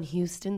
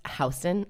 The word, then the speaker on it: Houston: But you know what it is, that thing when we lived in New York and someone would be like, I'm looking for a place on Houston Street, but I'm like, it's pronounced Houston.